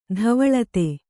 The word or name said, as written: ಧವಲಿತ